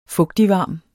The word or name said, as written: fugtigvarm